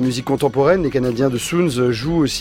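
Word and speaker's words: Suuns: Soons qu'il faut écrire comme soleil au pluriel en anglais avec double U, et qu'on prononce donc Soons comme le chiffre zéro en thaï.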